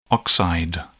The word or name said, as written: oxide